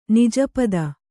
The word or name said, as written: ನಿಜಪದ